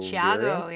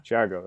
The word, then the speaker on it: Thiago: Thiago, yeah. – Thiago, that's how you say it.